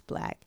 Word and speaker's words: 6LACK: Who's pronounced Six Black to some people, but it's Black.